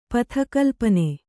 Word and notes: ಪಥಕಲ್ಪನೆ: Kannada

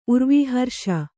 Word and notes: ಉರ್ವೀಹರ್ಷ: kannada

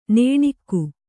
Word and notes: ನಣ್ಕೆ: kannada

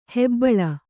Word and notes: ಹೆಬ್ಬುಲಿ: hebbuli

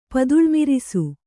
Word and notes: ಪದುಳಮಿರಿಸು: Kannada

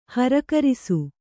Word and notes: ಹರಕರಿಸು: harakarisu

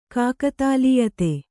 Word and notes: ಕಾಕತಾಲೀಯತೆ: kākatālīyate

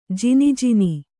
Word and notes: ಜ್ಞಾನಿ: jñāni

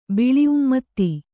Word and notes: ಬಿಳಿಉಮ್ಮತ್ತಿ: kannada

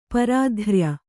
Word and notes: ಪಾರತ್ರಯ: pāratraya

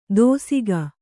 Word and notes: ದೋಸಿಗ: dōsiga

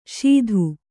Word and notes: ಶೀಧು: śidhu

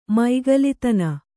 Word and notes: ಮೈಗಲಿತನ: maigalitana

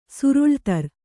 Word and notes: ಸರಳದಾರು: kannada

